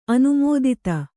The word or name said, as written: ಅನುಮೋದಿತ